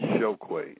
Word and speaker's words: Chokwe: It's pronounced like S-H, Chokwe. It's spelled C, like Chokwe.